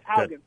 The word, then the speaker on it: Haugen: Hagen, I think. I think Haugen. You pronounce it Hagen or Haugen. Okay, okay. – No, Haugen. Francis Haugen.